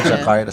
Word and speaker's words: Jakarta: Am I saying it right? Jakarta, Jakarta, sorry. – Perfect.